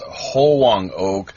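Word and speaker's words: Hwang-ok: My pronunciation of her name, and it can vary, of course, is Hoang Ok, which apparently means Jade Princess.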